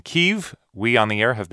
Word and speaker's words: Kyiv: So it was actually in 2006, the State Department adopted that pronunciation and a different spelling, K-Y-I-V, rather than K-I-E-V, because it sounds more Ukrainian rather than Russian.